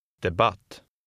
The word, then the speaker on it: debatt: In number 7, the second syllable is stressed. Read number 7. – Debatt.